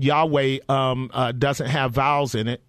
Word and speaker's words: Yahweh: So in order to hear it, the English language has to put the vowels in there to pronounce the Yahweh sound.